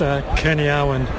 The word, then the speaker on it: Kearney-Arwen: Can we give us a pronunciation of your last name? Make me feel like I'm not that much of an idiot. – It's pretty easy to pronounce. It's Kearney-Arwen. – Kearney-Arwen. – That's it, yep.